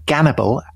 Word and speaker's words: Gannibal: And Dominic, today you have someone with the name Gannibal, which is, I gather, the Russian, the way the Russians pronounce Hannibal, the great military hero, conqueror, of the Carthaginians.